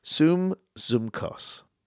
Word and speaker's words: sympsychos: And that word is, I'm going to try and say it right here, sumpsychos, okay? S-Y-M-P-S-Y-C-H-O-S.